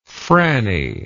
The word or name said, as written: frannie